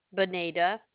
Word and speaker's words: Bonita: Bonita. – How do you spell that? – B-O-N-I-T-A. – Bonita. V-O-N-I-T-A.